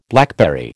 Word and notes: blackberry: American English

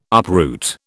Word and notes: uproot: British English

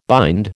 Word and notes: bind: American English

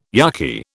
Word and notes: yucky: British English

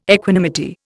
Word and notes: equanimity: Indian

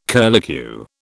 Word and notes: curlicue: British English

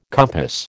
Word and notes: compass: American English

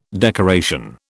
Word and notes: decoration: British English

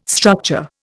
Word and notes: structure: Indian

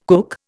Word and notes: cook: Indian

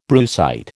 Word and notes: brucite: American English

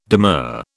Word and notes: demur: British English

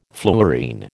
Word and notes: fluorine: American English

